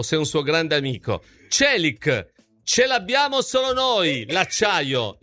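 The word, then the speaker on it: Çelik: Si pronuncia Celic. – Celic. – No, Celic. Celic, che significa acciaio.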